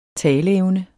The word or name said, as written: taleevne